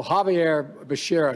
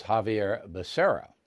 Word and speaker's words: Javier Becerra: For Secretary of Health and Education Service, I nominated Javier Baccarria. You know, Javier Becerra, excuse me. – It's Javier Becerra. Now, anyone can mispronounce a name. I do it. Anyone can do that.